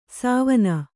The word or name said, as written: ಸಾವಣ